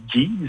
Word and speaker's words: Geez: Et le groupe, comment ça se prononce? Je suis même pas au courant. Je ne les connais pas. Est-ce que c'est « Geez » Oui, en fait, je crois que c'est Geez. Ça fait pas longtemps que je sais moi aussi que ça se prononce comme ça.